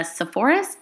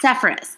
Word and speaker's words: Sepphoris: His contribution to architecture and stratigraphy of the ancient sites of the Sepphoris, did I say that wrong? I think I did. – Sepphoris